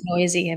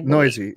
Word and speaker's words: Neuse: I think this is, I don't think I pronounced Sheldon Neuse. – Yeah, it's Noisy. – Noisy, yeah. – Yeah. I spell it out. I spell the word out Noisy, so I remember.